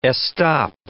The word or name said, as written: estop